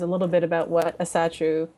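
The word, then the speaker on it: Ásatrú: Okay, so this one, in essence, this individual performs ritual with, I'm going to say this wrong, Asatru? – That's how I pronounce it, sure.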